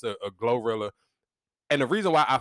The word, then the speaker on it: Glorilla: Yeah, Glorilla. – Okay, I didn't know that. I never knew it. Because the way it's spelled, it kind of almost looked like Gorilla, but it's Glorilla.